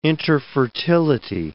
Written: interfertility

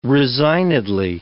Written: resignedly

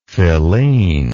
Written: filene

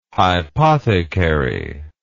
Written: hypothecary

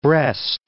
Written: Brest

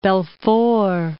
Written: Belfort

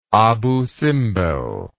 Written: Abu Simbel

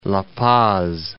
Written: La Paz

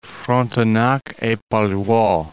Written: Frontenac et Palluau

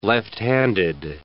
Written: left-handed

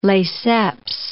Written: Lesseps

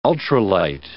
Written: ultralight

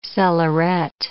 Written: cellaret

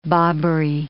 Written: bobbery

